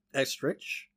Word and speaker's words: Estrich: I was going to try my best to Estrich. – Estrich? – Estrich. – Yeah, usually CH is a K sound.